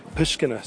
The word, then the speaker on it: Pushkina: And I want to say it right, the street I mentioned that we're going to be doing our classes there in Uman, Ukraine, is Pushkina Street. There we go, I said it right, thank God.